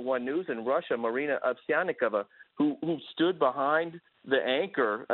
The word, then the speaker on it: Ovsyannikova: Ovsyannikova.